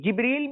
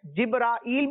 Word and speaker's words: جبریل: جبریل۔ جی، جبریل بھی ہے، جبرائیل بھی پڑھ سکتے ہیں اور جبرائیل بھی پڑھ سکتے ہیں، اس کے تین تلفظ بیان کیے جاتے ہیں، جبریل، جبرائیل اور جبرائیل